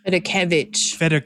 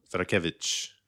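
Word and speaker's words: Federkiewicz: The accent is on the Kev. It's on the Kev. – Federkevich. – Federkevich.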